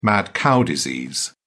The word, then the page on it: mad cow disease: British English